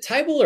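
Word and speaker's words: Thybulle: Is it Tybull or Thibault? I say it's Tybull, but I might be reading too much into the Basketball Reference pronunciation, and that's what most of the announcers say, I believe, and I try and go by like what the home teams are saying as well, but...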